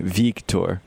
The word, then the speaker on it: Victor: I think it's actually Victor Arano. At least according to his pronunciation guide, it's V-E-E-K. Victor.